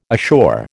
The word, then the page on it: ashore: American English